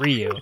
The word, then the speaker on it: Ryu: you're pronouncing your son's name incorrectly, right? It's Ryu.